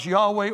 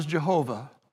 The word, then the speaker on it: Yahweh: That is a way that the translators have used to translate the four-letter word that we pronounce Yahweh, or in some versions is pronounced as Jehovah.